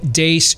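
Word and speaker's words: Deace: I am Steve Dace.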